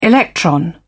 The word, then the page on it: electron: British English